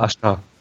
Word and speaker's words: Asuka: Ähm, äh, Ashka heißt, schreibt man ja auch A-S-U-K-A und das U ist stumm. Man sagt Aha. einfach nur Ashka. Das Aha. U ist einfach weg.